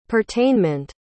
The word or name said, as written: pertainment